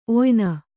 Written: ಒಯ್ನ

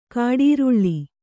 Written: ಕಾಡರಳೆ

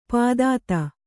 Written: ಪಾಥೋದಿ

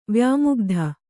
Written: ವ್ಯಾಮುಗ್ಧ

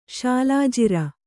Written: ಶಾಲಾಜಿರ

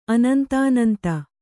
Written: ಅನಂತಾನಂತ

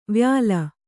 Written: ವ್ಯಾಲ

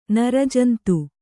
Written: ನರಜಂತು

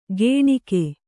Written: ಗೇಣಿಕೆ

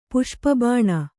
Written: ಪುಷ್ಪಬಾಣ

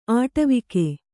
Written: ಆಟವಿಕ

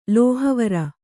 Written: ಲೋಹವಾರಿ

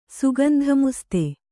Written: ಸುಗಂಧಮುಸ್ತೆ

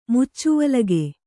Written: ಮುಚ್ಚುವಲಗೆ

